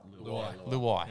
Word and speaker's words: Luai: They found a good pairing, Cleary and Luau. – Mm. – Is that right? – Luai. – Yeah.